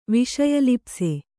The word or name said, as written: ವಿಷಯಲಿಪ್ಸೆ